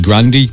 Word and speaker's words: Grande: Her surname is actually Grandy, but she's just done with correcting people, so she's just accepted it.